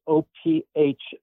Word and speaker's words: Ophicleide: Ophioclides. What was called it? I'm not even sure if I'm pronouncing that right. It's not a word we say out loud very often these days. Ophicleide.